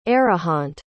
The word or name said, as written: arihant